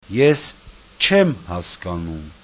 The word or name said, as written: eschemhaskanum